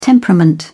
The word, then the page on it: temperament: British English